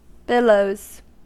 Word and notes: billows: American English

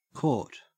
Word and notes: court: Australian English

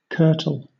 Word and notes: kirtle: British English

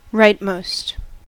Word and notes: rightmost: American English

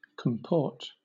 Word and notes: comport: British English

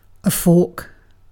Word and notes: fork: British English

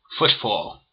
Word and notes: footfall: American English

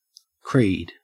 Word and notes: creed: Australian English

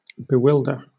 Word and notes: bewilder: British English

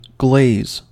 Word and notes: glaze: American English

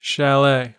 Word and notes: chalet: American English

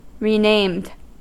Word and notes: renamed: American English

